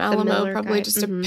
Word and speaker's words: Alamo: And I wonder if he, I feel like he wanted it pronounced Alamo just to like be like, it's Frankenstein. – Yeah. – Yeah. No, it's Alamo.